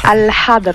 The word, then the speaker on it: Al-Hadr: Alors je sais pas si je le prononce bien, je parle pas l'arabe, désolé. Il s'appelle Al-Hadr. Non, comment tu le prononces hein? Al-Hadr. – Ah voilà, bon, effectivement.